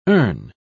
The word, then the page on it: ern: British English